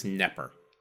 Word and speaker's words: Knepper: K-N-E-P-P-E-R. I'm assuming that's Knepper. – Yeah, like Bob Knepper. – Like Bob, that's how I came up with that pronunciation.